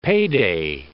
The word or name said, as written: payday